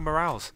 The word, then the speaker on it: Morales: I've just been mispronouncing it this entire time. But what? – Anyway, I'll say Morales from now on.